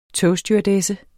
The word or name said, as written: togstewardesse